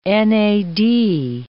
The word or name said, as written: NAD